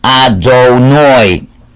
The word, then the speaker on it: Adonai: If we're not praying, we'll always say Hashem, but if we're practicing our reading, when you see the yud and the hey and the vav and the hey, you would be pronouncing it Adonai.